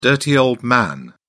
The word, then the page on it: dirty old man: British English